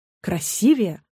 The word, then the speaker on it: красивее: Ударение в сравнительной степени «красивее» падает только на второй слог, и никак иначе.